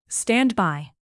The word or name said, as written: standby